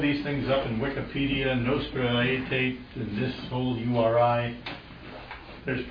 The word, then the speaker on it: Nostra Aetate: A-E-T-A-T-E, that's a name. Nostra Aetate.